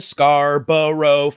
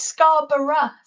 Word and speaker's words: Scarborough: Because in American English, we'll be like, are you going to Scarborough Fair? But I've never heard a British person sing the song. – It's Scarborough. So it's still three syllables, just slightly different syllables.